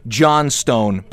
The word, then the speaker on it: Johnstone: His name was Davy Johnston, or I've also heard it pronounced Johnstone, but that I think would be inside of a Flintstones episode.